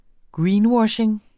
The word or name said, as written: greenwashing